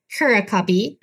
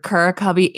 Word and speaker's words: Curacubby: Am I saying that right? – You are. And to be honest, there's not like a designated way to pronounce it. I've heard Curacubby and Curacubby from people. So, okay, I'm going to stick with Curacubby because it just came naturally to me.